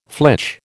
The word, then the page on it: flitch: American English